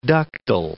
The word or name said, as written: ductal